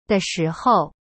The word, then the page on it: 的时候: de shi hou